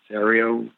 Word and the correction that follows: Arpaio: Yeah, Joe Arpaio.